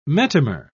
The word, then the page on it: metamer: British English